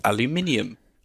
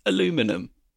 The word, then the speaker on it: aluminium: It seems to be sheets of aluminium, or for our American listeners, aluminum. – For our wrong listeners. – or for our American listeners, aluminium, which is still the correct way of pronouncing it.